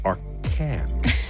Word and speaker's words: Arcand: We have more questions coming up on the X-ray universe with Kim Arcan. I say that right. It's French, but I'm Americanizing it.